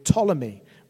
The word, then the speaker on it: Ptolemy: And in the south, we have a man called Ptolemy, with a silent P at the front.